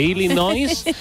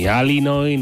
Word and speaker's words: Illinois: que no se dice ni Illinois, ni Alini, ni Alioli, ni nada por el estilo. No, es Illinois en todo caso.